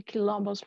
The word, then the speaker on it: quilombos: which is spelled Q-U-I-L-O-M-B-O-S, quilombos.